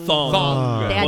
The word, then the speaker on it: thong: No sabía que tanga en inglés se decía tong. – Nicole, tong. – En realidad se pronuncia thong.